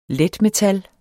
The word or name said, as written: letmetal